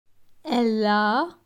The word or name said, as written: Elle a